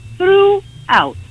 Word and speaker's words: throughout: Per esempio, ecco, la parola throughout, che c'ha il GH silenzioso in mezzo. Quella è una parola che manda al manicomio. – Un delirio. – Tutti. Allora, la maniera migliore per pronunciarla è quel GH lo trasformate in un trattino. Diventa, cioè, come se non ci fosse, al posto del GH ci va un trattino. – Ecco. – E diventa throughout.